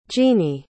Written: jeanee

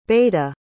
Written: beda

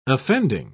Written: offending

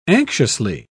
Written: anxiously

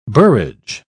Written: borage

